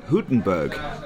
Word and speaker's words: Hülkenberg: And he says, Hülkenberg. And I said, oh, okay. And that's how he pronounced it.